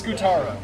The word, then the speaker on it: Scutaro: Marco Scudero. I'm very excited for the next one. – I believe it's Scutaro. – Is it? – Oh, wow. – Yeah, from the pronunciation of MVP baseball.